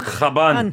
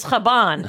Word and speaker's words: Chabon: Is it Chabon? Chabon? Chabon? I mean, what is it? – It's Chabon. – It's Chabon. That's what I thought.